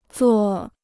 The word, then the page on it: 座: zuò